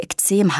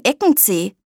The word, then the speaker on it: Ekzem: Ich habe eine Krankheit, die Ekzem heißt. Das ist ziemlich schwer auszusprechen. Früher habe ich das immer falsch ausgesprochen. Ich habe mein Eckenzeh, habe ich immer gesagt, wenn das Jucken anfing.